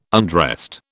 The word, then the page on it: undressed: British English